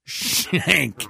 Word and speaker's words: Schenck: In response to the announced news conference, National Clergy Council President, the Reverend Bob Schenck pronounced, Shank.